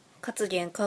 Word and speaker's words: カツゲン: カツゲンかっこピクミンの発音ですみたいな。